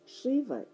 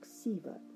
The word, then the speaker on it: Шива: Шива иногда произносится как Сива.